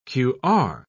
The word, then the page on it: qr: British English